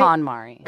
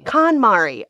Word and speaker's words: KonMari: Am I pronouncing that right? – KonMari. – KonMari. Oh, gosh.